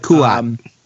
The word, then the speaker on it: Kuat: Opens up that, I don't know how you guys say this, because there's no good pronunciation, but Kuat. – Kuat. Yeah. I mean, like that.